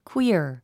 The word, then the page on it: queer: kwíər; クゥイア